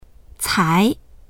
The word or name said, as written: cai2